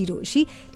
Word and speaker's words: Rochie: मेरा नाम है मिर्ची रोशी, काम है आपसे प्यार करना, नाम के स्पेलिंग है ROCHIE।